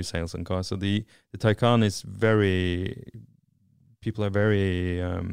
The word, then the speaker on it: Taycan: I'm not sure how you pronounce it in English, because in Norway it's Taycan. But Taycan is right, yeah.